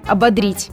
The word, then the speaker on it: ободрить: Сомнения прочь, все правильно, ободрить и никаких подвохов. Проблема лишь в том, что даже среди довольно крупных интернет-ресурсов встречаются недостоверные данные. Так, на нескольких сайтах я встретила утверждение, что ударение якобы нужно ставить на второй слог, но словарь ударений русского языка, к счастью, непреклонен. Единственный верный вариант – ободрить.